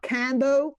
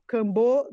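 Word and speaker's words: Kambo: Please, everybody, not Cambo, Cambo, like the second emphasis on the second syllable.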